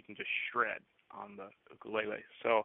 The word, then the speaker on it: ukulele: ukuleles or ukuleles. Correctly pronounced ukulele, it means jumping flea in Hawaiian, and in regards to electric ukuleles, yes, there are.